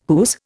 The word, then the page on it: whose: Indian